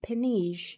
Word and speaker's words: penige: Something that backs this theory up is the fact that the old English word for penny is the word penige, I think is how it's pronounced. It's P-E-N-I-G-E.